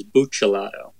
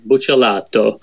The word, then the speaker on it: Buccellato: Somewhere along the way, I'm probably traveling in Italy, I learned the actual correct pronunciation is Buccellato. – Buccellato, right? Okay. – Yeah, Buccellato.